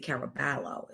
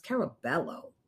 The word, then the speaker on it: Caraballo: We know he's Hispanic, not Italian. So I don't want to say Caraballo. It's Caraballo.